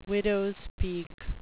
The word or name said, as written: widows peak